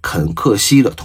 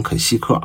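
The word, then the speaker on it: 肯·克西: 行，咱们就先聊一下这个电影的简介啊，《飞跃疯人院》改编自美国作家肯克西的同名小说。我老把这个肯克西念成肯西克啊，如果后边有口误，那请大家多多原谅啊。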